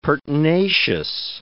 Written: pertinacious

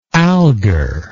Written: algar

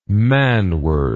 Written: manward

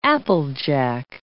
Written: applejack

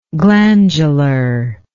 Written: glandularly

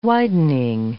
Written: widening

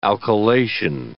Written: alkylation

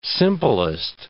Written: simplest

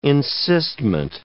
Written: encystment

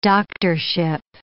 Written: doctorship

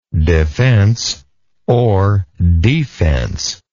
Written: defenselessness

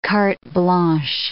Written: carte blanche

